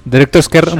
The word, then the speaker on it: Director's Cut: ¿Director's Cut Mochis.